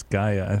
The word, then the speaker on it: Gaia: It's not gay. It's Gaia.